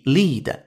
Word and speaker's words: lead: 这里边的 lead 就是 L E A D lead 那个单词，作为名词词性表示铅时候的一个读音，叫 lead。